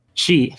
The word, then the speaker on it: Xi: Devotion was removed from Steam shortly after its release in February 2019, after the meme critical of Chinese President Xi Xi Jinping. Did I say that right? – Uh, she. she. She. – She. Yeah, pronounced like like her. So she.